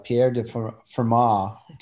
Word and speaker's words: Pierre de Fermat: Yeah, so he was French, Pierre de Fermat, to give the French pronunciation.